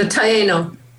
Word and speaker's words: Taino: I, I think it's the Tano. I think that's how you say it. – The Taino. – Taino. Okay. Yeah.